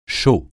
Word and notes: chaux: français

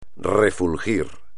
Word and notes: refulgir: espagnol

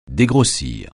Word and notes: dégrossir: français

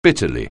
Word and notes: bitterly: anglais

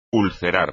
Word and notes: ulcerar: espagnol

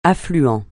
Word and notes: affluent: French

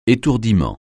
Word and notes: étourdiment: français

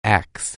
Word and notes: axe: anglais

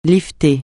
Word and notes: lifter: francais

